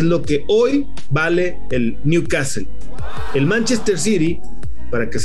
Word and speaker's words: Newcastle: Se llama Newcastle, las urracas para el castellano Newcastle, el nuevo castillo, porque la pronunciación en inglés es Newcastle.